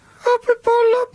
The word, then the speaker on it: Hoppipolla: Hoppipolla.